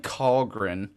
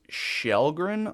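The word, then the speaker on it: Källgren: It looks like Kahlgren. It's pronounced Shellgren, I believe.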